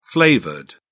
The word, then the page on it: flavored: British English